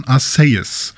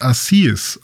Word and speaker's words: Arceus: Im Japanischen sagt man Arceus. Also mit dem mit einem Say quasi als okay. ähm, CE. Und äh, es gab aber eine Mispronunciation sozusagen von Ryan Reynolds im Detective Pikachu-Film. Da wurde es nämlich als Assis ausgesprochen.